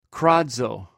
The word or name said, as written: krazw